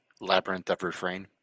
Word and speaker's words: Labyrinth of Refrain: Art of Rally, Curse of the Dead Gods, Eve Online, I can never say this, Labyrinth of Refrain, butchering that probably. Say it, Chief. Or anyone. – Labyrinth of Refrain.